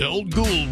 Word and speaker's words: Old Ghoul: Old Ghoul Weekend. Rhymes with old school.